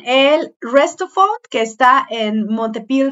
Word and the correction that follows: Montpellier: Francia. ¿O cómo se pronuncia, amiga? Montpellier, Montpellier.